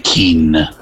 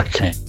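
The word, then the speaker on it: Kean: Ma si può fare l'opinionista e dire Kin? Non sapete che si pronuncia Ken.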